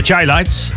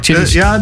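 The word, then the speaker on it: Chi-Lites: Ja, maar hoe spreek je nou deze bandnaam uit? – De Chai Lights. – Oh, de Chai Lights, ja. – Ja. – Ik las even heel snel. Ik dacht, Chitties.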